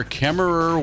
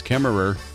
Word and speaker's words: Kemmerer: James Cash Penny opened his first retail store, Kemmerer, Wyoming. Don't forget that extra syllable in Kemmerer. It's not just Kemmer, it's Kemmerer.